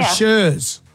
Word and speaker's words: Chers: Shers or Shers? – Shers. – Because the girls here have written your name as C-H-E-R-S. Is that how you spell That's it? – That's how you spell it.